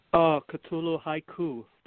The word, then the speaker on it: Cthulhu Haiku: Cthulhu Haiku.